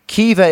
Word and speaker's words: Caoimhe: So an Irish person tweeted, Kiva is my dog's name, but it's spelt Cuiva, C-A-O-I-M-H-E.